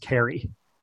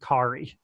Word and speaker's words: Kari: It never occurred to me that her name is pronounced Carrie. I always went with Kari.